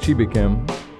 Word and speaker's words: Chibuikem: You're listening to the mix right now of DJ Chibuikem. I think I said it right finally. What's up, man? – Doing well. Nicely done.